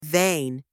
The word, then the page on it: vein: anglais